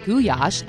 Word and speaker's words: goulash: When we come back, we're going to learn all about goulash, or goulash, as it is pronounced in Hungary, from Budapest-based Hungarian food expert Carolyn Benfalvi.